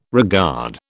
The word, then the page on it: regard: British English